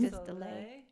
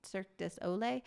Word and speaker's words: Cirque du Soleil: We're going to make it's Cirque du Soleil. Is that how you say it? – It's like the theme? – Cirque du Soleil. – Cirque du Soleil.